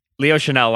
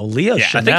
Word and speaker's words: Chenal: Chanel, like Chanel. Is it spelled like Chanel? – Like Coco Chanel. – C-H-E-N-A-L. – Oh, so it's a little different. – Yeah. – Oh, wow, what a beautiful name, though. Leo Chanel. – I think it's pronounced the same way, though, isn't it? – I'm 98% sure, though.